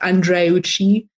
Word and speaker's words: Andreucci: And my surname, which may be a little bit foreign for some or exotic, if you remember, Andre and Gucci, but without the G. So it's like Andreucci.